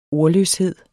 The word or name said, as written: ordløshed